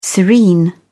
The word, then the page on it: serene: British English